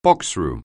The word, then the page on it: boxroom: anglais